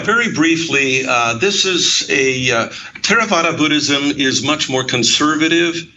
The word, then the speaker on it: Theravada: And I'll take a shot at pronouncing it Theravada, but that, I'm sure I've got that wrong. – You got it right.